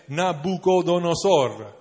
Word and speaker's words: Nabucodonosor: sarebbe caduto nelle mani del re Nabucodonosor.